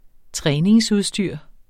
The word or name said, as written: træningsudstyr